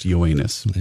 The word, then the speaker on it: Yoenis: A baseball note, Mets outfielder Yonis Cespedes will miss the rest of the season. He's going to undergo surgery on both heels. – I believe his first name is pronounced Yoenis.